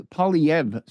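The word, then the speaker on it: Poilievre: Likely Polyev. So the R-E are silent at the end, as I would know if I were more cultured and spoke.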